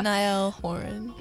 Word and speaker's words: Niall Horan: Niall Horan.